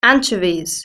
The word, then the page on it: anchovies: British English